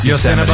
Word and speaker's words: Yosemite: You didn't say it right, but we know what you meant. Yeah. Yosemite. – Yosemite.